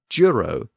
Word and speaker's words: juro: The Latin word for swear was juro, now being pronounced as juro in medieval Latin.